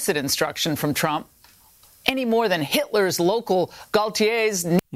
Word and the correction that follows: Gauleiter: Now, that word there was Gauleiters. She mispronounced that.